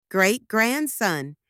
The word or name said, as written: great-grandson